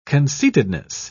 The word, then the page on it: conceitedness: British English